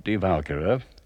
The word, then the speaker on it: Die Walküre: in which we take up Die Walküre, which is how Wagner pronounced it, with the accent on the first syllable Die Walküre is the second drama of the cycle, a cycle based mainly on the myths of Northern Europe and of Greece.